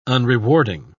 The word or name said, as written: unrewarding